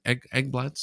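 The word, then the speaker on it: Ekblad: Aaron Eggblad, am I pronounced that right? Eggblad, Stevie, is that right? Aaron Eggblad. There we go. I thought so. Okay. Aaron Eggblad with Florida goes into the boards trying to gather the puck.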